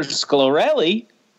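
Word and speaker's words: Scolari: And Peter Sciarelli. That's how you say it. Sciarelli.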